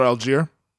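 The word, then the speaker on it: Allgeier: Algier, I think his name is. Is that how you say it? – Tyler Algier.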